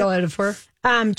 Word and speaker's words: Chiwetel Ejiofor: Lockdown with Anne Hathaway and Chiwetel Ejiofor. I can't pronounce it properly. Chiwetel Ejiofor.